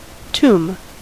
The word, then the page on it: tombe: [tɔ̃b]; France